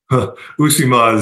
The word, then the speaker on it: Uusimaa: Uusimaa. Is it in Finland? – Uusimaa is the region.